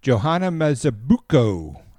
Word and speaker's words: Johanna Mazibuko: Johanna Mazibuko. I think I said that right. I think you did. I think so too.